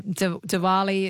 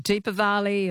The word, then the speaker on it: Diwali: Yeah, Diwali, it's called, or it could be called Deepavali or Diwali. Yeah, it's got all sorts of pronunciations.